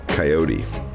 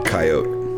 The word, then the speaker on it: Coyote: Still, you mispronounce his name. Of two out of three possibilities, you choose the third. Coyote. Say it like a prospector if you must. Coyote. Only marginally acceptable. But Coyote, myself, and this town are forgiving like the weather. Only sometimes harshly abrupt like earthquakes. We prefer the Spanish. Say every letter as it's always pronounced.